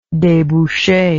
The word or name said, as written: debouche